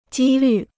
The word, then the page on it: 几率: jī lǜ